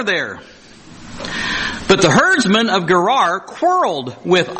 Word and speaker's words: Gerar: If I ask you about the herdsmen of, I can't even pronounce it, Gerar, G-E-R-A-R.